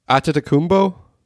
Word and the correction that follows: Antetokounmpo: No, I think close. I added a T there. – Giannis Antetokounmpo.